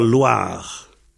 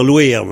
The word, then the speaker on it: loar: Alors, donc, un néol à bar, war loir. Alors, au lieu de loir, on peut dire louir, mais je veux pas le dire tout le temps. Ça s'écrit L-O-A-R, ça s'écrit loir, hein.